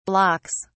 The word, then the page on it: blocks: British English